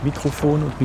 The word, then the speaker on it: Mikrofon: Mikrofon? – Wir haben Aussprache-Datenbank nachgeguckt, es ist beides möglich. Mikrofon und Mikrofon.